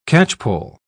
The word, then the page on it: catchpoll: British English